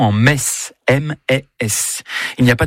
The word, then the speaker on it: Metz: Le meilleur moyen de mémoriser la prononciation correcte de la ville de Metz est certainement de se rendre au stade Saint-Symphorien, où vous répéterez jusqu'à plus soif. Ici, c'est... – Metz. – Ici, c'est...